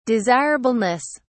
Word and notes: desirableness: British English

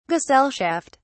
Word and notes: gesellschaft: British English

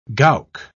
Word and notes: gowk: British English

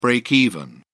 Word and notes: breakeven: British English

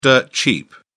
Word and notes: dirt cheap: British English

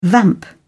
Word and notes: vamp: British English

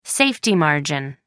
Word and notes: safety margin: American English

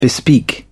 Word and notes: bespeak: British English

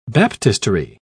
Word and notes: baptistery: British English

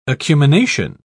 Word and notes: acumination: British English

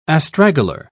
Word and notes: astragalar: British English

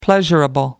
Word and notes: pleasurable: American English

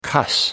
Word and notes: cuss: American English

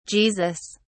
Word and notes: Jesus: British English

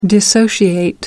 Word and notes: dissociate: American English

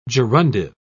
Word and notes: gerundive: British English

